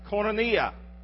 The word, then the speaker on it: koinonia: That word fellowship in the Greek is a word we pronounce koinonia.